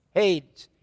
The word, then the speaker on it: Hades: Hades. I can't say it right. – You said it right. You got it.